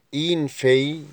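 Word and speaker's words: une fille: كلمات درس اليوم: ان غارسون، ان غارسون او ان غارسون يعني ولد، اين في يعني بنت، اين في يعني بنت، ما ننطقش ال ال،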